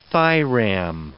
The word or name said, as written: thiram